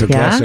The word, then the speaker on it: Ocasek: Because they got Eddie Money, then Rick Ocasek. I mean, comes in threes. – Because I always called him Rick Ocasek also. – Yeah, it's Ocasek.